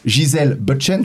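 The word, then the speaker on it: Gisele Bündchen: Par exemple, la mannequin Gisèle Bündchen, je sais pas si je prononce bien, ouais, c'est ça.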